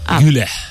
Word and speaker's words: Güler: Creo que Arda es Arda Güler. – Güler. ¿Y cómo he dicho? Güler. – Sí, es más bien una...